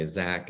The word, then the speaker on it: Xaque: My name is Zach Gruber. And so in 1820, Franz X Gruber, my first name is spelled with an X, by the way. Zach is spelled X-A-Q-U-E, which is derived from Xavier.